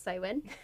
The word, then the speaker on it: Samhain: So Halloween originated in Celtic countries with a festival pronounced Samhain, that you spell like Samhain, but I have found out, luckily in time for recording, it's pronounced Samhain.